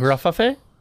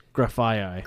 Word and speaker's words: Grafaiai: Tell me how to pronounce this. G-R-A-F-A-I-A-I. – How do you think that's pronounced? – Graffafe? – No, one more time. – Graf... How do you spell it? – G-R-A-F-A-I-A-I. – Graffaye. – Close. It's Graffaye.